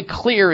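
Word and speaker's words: Xlear: And by the way, Clear is spelled with an X, X-L-E-A-R,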